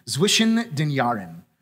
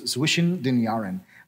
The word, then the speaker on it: Zwischen den Jahren: There's a term that they use, which is, I'm gonna butcher this pronunciation, Zwischen den Jahren, which means between the years. Between the years, Zwischen den Jahren.